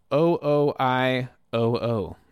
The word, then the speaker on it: OOIOO: This is a band called OOI. O-O. That's how you say it. Two O's and I and two more O's.